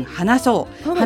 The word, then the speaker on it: 花草: そうなんですよ、この度そこでのハンドルネームっていうんですかね、 J さんと三島市さんも応援してくださって、 4 月 19 日にハーバルケアガーデン花そう、花そうは花草と書いて花草と読ませます。